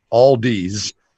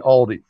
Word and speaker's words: Aldi: You're saying Aldi's, but it uh-huh. should be Aldi.